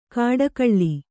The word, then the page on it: ಕಾಡಕಳ್ಳಿ: kāḍakaḷḷi